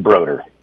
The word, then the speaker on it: Brodeur: Is it Brodeur or Brodeur? – I pronounce it Brodeur.